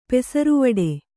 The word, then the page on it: ಪೆಸರ್ವಡೆ: pesarvaḍe